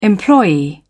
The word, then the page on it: employee: British English